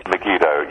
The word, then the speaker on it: Megiddo: Megiddo. Megiddo. – Megiddo, yes.